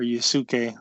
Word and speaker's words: Yasuke: I think that's how you pronounce it, or Yusuke, who knows. – I think you got it right.